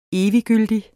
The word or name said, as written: eviggyldig